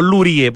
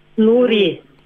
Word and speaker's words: Lurie: Curadoras de la muestra de Boris, ¿cómo se pronuncia? ¿Luri o Lurie? Porque no creo que la S pronuncio, ¿sí? – Luri. – Luri.